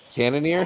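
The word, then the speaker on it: Cannonier: Yeah, Cannoneer. Is that how it's pronounced? – Yeah. Good job.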